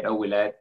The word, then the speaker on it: Ouilhet: And I just said Ouellet because that's how my dad said it and how my grandpa said it, but I think they were both wrong.